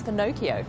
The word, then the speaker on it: Finocchio: Its Italian name is Finocchio, rhymes with Pinocchio.